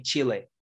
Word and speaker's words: Chile: Is it pronounced, yeah, Chile? – It's controversial. It depends on which English speaker you ask to, but most Americans will say Chile.